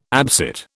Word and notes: absit: British English

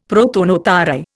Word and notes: prothonotary: Indian

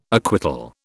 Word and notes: acquittal: British English

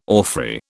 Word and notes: orphrey: British English

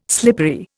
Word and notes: slippery: Indian

